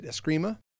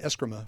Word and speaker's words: Eskrima: At the time, I did not know to pronounce it Eskrima, so we said Eskrima for years.